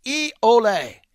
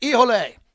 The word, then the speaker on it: Hijole: Ijole, that's how you say it. Ijole, the official tequila of the show. It's spelled H-I-J-O-L-E.